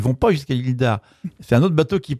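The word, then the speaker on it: d'Arz: C'est drôle parce que moi, ça m'avait surpris quand j'étais allé aux Moines, parce que l'île d'Ar est à côté. L'île d'Ar, je ne sais pas si on dit ça. – D'Ar. – On ne prononce pas le Z.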